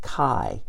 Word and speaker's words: Chi: The letter X in the Greek alphabet is pronounced Chi.